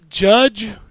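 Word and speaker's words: Jydge: Judge. It's called Judge, but it's spelled Jige. – What? – It's J Y D G E. – Is this still pronounced Judge? Then? – Yeah, it's pronounced Judge.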